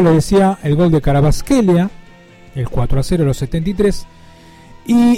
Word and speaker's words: Kvaratskhelia: Salió bien, ¿no? Kvaratskhelia. Ahora, si ustedes lo ven escrito, es muy difícil. Pero creo que me defendí bastante bien. Porque se escribe con Kvaratskhelia. Pero yo le voy a decir que creo que está bien dicho.